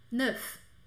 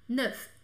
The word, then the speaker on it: neuf: Porque se escreve com F e às vezes a gente quer falar, né? Então eu só falo esse F, eu só pronuncio ele quando ele tá sozinho. Neuf. Neuf.